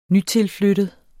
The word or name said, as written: nytilflyttet